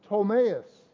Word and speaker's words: Ptolemais: This word, the P is silent. It's Ptolemais.